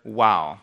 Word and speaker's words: Waw: But anyway, that's the Hebrew letter Wow. Like we'd say W-A-W, as far as pronunciation goes, Wow.